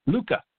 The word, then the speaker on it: Lucca: Am I saying that right? Lucca, Italy? – Lucca. – Okay, Lucca, Italy.